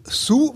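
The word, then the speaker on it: Hsu: Äh, dann, dann spielt auch mit ähm, äh, Stephanie Sue, wird sie, glaube ich, ausgesprochen.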